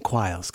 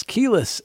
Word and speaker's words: Quiles: And yes, I admit it, I've been mispronouncing her name for all this time. I was saying Quiles, because it is spelled Q-U-I-L-E-S.